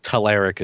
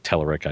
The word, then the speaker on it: Telerik: Okay, because I've always heard Telerik is the right way, and somebody go and tell Hanselman that it should be pronounced Telerik, I guess.